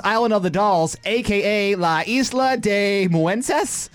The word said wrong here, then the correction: Muñecas: La Isla de las Muñecas. – I got a D in Spanish. – Muñecas. – Muñecas.